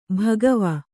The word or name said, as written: ಭಗವಾ